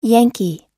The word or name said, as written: yankee